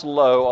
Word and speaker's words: lo: And that little Hebrew word, pronounced lo, although spelled two different ways, can either mean not, or it can mean to him.